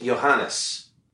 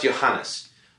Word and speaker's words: Ioannes: His name is spelled I-O-A-N-N-E-S. Do you know how that's pronounced? Of course you don't. But it's pronounced Johannes. It's pronounced Johannes.